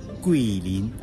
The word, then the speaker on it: Guilin: Esta ciudad se llama Quilin. Quilin, G-U-I-L-I-N. – ¿Sí? – Pero en la fonética china, Qui se, se pronuncia Qui. Entonces, el nombre de esta ciudad es Quilin. Por favor, no pronuncien chino.